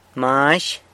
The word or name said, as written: Mazh